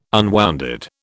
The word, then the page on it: unwounded: British English